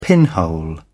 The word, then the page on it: pinhole: British English